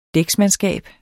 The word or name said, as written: dæksmandskab